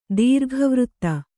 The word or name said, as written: ದೀರ್ಘವೃತ್ತ